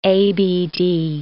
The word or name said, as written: ABD